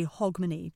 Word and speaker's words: Hogmanay: I say Hogmanay because I'm from Edinburgh, and that's how we say it, but you might also hear Hogmanay or Hogmanay or some other slight variation. Typically today, Hogmanay just means the last day of the year itself.